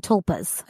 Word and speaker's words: tulpa: Thought forms and tulpas. A tulpa is something that, am I pronouncing that right? Mm-hmm. Tulpa? – Yep.